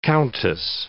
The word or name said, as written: countess